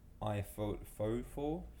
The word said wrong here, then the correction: Ejiofor: The guy that did 12 Years a Slave, the British one. – Never seen it. – The guy that was in... – Chiwetel Ejiofor. That dude.